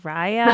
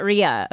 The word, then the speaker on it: Rhea: I actually initially thought it was Rhea and then I was like, well, maybe it's Raya and I'm saying it wrong, but Rhea. Okay, Rhea Collective. – No, that's perfect.